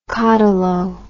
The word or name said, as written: cotylo